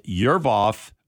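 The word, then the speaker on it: Yervoy: The Roche, they're saying Roche's new AG drug, Zeboraf, that's spelled Z-E-L-B-O-R-A-F, for treating molecular roots of tumors and therapies like Yervoff. Okay, again, I'm not good with these pronunciations. So it's Y-E-R-V-O-Y.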